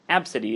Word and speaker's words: Abcde: Before a flight from California to Texas this month, the girl's moniker, Absidy, is pronounced Absidy, caught the attention of an employee with Southwest Airlines. That really loses its effect when you're reading it out loud. If anybody's listening to this recording, they're just going to be confused. Absidy is spelled A-B-C-D-E.